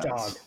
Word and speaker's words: Dag: Is it pronounced Doug or Dag? – Dog is what he says. – Dog. That's right. Dog. Yeah. D-A-G.